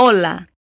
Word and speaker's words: hola: O é a sílaba mais forte, tá? Repete. Hola!